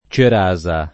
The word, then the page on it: cerasa: lingua italiana